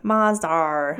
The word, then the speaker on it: Mazda: Mazda. Or as the Brits say, Mazdar.